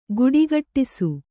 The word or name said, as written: ಘುಡುಘುಡಿಸು